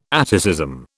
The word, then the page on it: atticism: British English